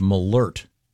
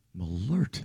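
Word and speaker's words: Malört: And this says it's pronounced Malort. – Malort. – There's a, was it an umlaut, the two dots over the O? This has a pronouncer saying Malort.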